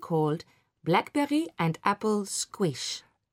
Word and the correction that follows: squash: It's pronounced squash. – Sorry, squash.